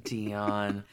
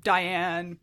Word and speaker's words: Dionne: Ed Sullivan was not able to pronounce her name correctly. He just got it right. Diane. D- yeah, he just got it wrong,